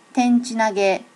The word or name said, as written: tenchi nage